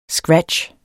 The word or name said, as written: scratch